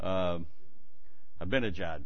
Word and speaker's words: Ahmadinejad: Or Aminadad, or what's his name? I can never remember how to pronounce his name. Ahmadinejad, yeah, from Iran.